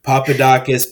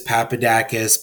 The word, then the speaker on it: Papadakis: Papadakis, Papadakis, Papadakis. I've heard them all.